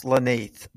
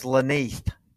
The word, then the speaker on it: Len'Neth: Possibly pronounced Lanith, based on what I've read in Bulldawg Illustrated's pronunciation guide, which is yeah. very helpful. – Yeah, let's look at that. – Let's I believe you have him down as Lanith.